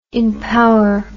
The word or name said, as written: impower